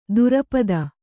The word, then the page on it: ದುರಪದ: durapada